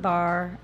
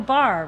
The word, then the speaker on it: barre: Because it's spelled B-A-R-R-E? – Yes.